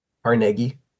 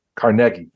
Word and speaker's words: Carnegie: The last thing I'll just throw out there that I encountered with spotted lanternfly over the last couple of months is, how do you say Carnegie? – Carnegie. – Carnegie. Thank you.